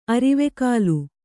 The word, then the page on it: ಅರಿವೆಕಾಲು: arivekālu